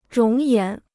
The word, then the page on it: 容颜: róng yán